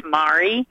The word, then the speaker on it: Mari: I've gone through life spelling and pronouncing my name. It's Mari. – Mari. – It's like okay. – Calamari.